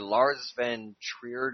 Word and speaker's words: Lars von Trier: By Lars von Trier. Am I pronouncing that right? – You are. Close enough.